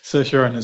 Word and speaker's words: Saoirse Ronan: Saoirse Ronan. – Saoirse Ronan, yeah, I always get the first name. Saoirse Ronan.